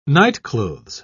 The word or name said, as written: nightclothes